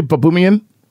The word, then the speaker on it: Baboumian: Am I saying your name correctly, Baboumian? – Yeah, Baboumian's perfect.